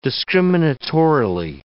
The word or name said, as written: discriminatorily